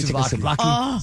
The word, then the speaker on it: souvlaki: Did I say it correctly? – Souvlaki.